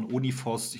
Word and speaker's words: 0N1 Force: Ja, es gab doch, es gab ein Projekt Uniforce, oh, ich glaube, äh, wird ausgesprochen Uniforce, ich glaube, geschrieben wird es 0N1 Force.